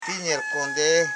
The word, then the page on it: nyilkon: nyil.kon; Koro